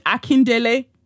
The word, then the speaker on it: Akindele: Akindele. Why did I say it like that? Ah, I said it. I said it like an English person. Richard Akindele. Oh my God. Yuck. – Richard Akindele.